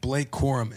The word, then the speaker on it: Blake Corum: And for everybody that's listening right now, listening, watching, whatever, we have a running back, Blake Corum.